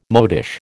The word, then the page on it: modish: American English